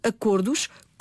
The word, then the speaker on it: acordos: No plural, essa pronúncia mantém-se fechada. Portanto, devemos dizer acordo, acordos, como lobo, lobos.